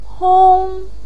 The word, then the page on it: hong1: pinyin